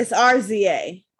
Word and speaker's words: RZA: It's RZA.